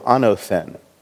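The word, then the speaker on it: anothen (ἄνωθεν): It is ano with a long O, anothen. Oops, I'm starting to write in Greek.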